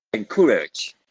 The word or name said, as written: encoura